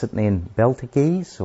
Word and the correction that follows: Betelgeuse: or something. – Betelgeuse.